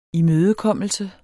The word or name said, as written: imødekommelse